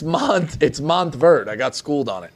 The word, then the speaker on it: Montverde: How do you say it? – Monteverde. – Nope. – Monteverde. – Nope. – Idiot. – Montverde.